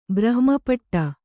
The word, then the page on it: ಬ್ರಹ್ಮಪಟ್ಟ: Kannada